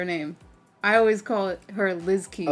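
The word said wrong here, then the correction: Li Ziqi: But I know that's not how you pronounce it. It's Lizziqi.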